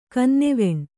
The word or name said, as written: ಕನ್ನೆವೆಣ್ಣು